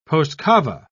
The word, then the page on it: postcava: British English